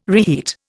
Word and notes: reheat: Indian

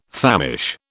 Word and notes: famish: British English

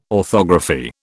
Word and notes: orthography: British English

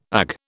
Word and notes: Ag: British English

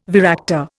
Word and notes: varactor: Indian

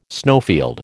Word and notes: snowfield: American English

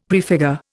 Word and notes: prefigure: Indian

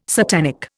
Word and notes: satanic: Indian

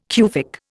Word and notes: Cufic: Indian

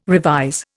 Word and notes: revise: Indian

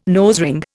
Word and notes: nosering: Indian

